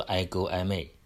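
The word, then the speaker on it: I go I may (ἐγώ εἰμί): 那我就是这个句式呢我们在汉语里面说我就是，这个句式好像很简单的一个回答，但是耶稣他的回答在原文里的耶稣的回答希腊文读作 I go I may，也就是 I am。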